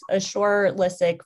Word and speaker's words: Ashur Lysak: It's Ashur Lysak from Pharma. For the record, my name does look like Asher Lysak, so no concerns on mispronouncing my name. It's definitely not how it looks.